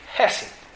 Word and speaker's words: hesed: It's the Hebrew word hesed. Now, I'm Southern, and I can't pronounce Hebrew real well. That's the best it's going to get. Hesed. Hesed is a covenant word.